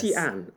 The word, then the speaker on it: Xi'an: Xi'an, I think it Xi'an. is the proper pronunciation. 对, Xi'an.